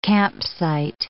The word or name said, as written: campsite